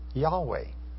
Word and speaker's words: Yahweh: But most likely, grammarians seem to be in pretty much agreement today that the correct pronunciation is Yahweh.